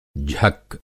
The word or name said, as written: झक्क